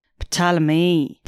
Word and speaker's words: Ptolemy: I don't know if that's how you pronounce his name, but P-T-O-L-E-M-Y. – Ptolemy.